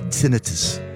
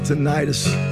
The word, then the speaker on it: tinnitus: I have a ringing in my ears, and my doctor pronounces it tinnitus. Not tinnitus.